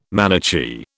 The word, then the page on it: Manichee: British English